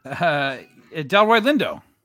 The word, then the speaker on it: Delroy Lindo: Delroy Lindo.